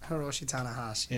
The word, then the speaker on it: Hiroshi Tanahashi: Hiroshi Tanahashi.